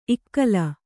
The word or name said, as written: ಇಕೇ